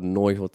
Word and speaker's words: noiho: Hear how Aupira rolled noiho together. So we see it written as two words, but we want to pronounce it as one word.